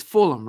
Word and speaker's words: Fulham: It's Fulham, right? – Fantastically, you didn't anything. – All right, I don't know how I knew that, but you know, as an American, when you see the spelling Fulham, our instincts is full ham.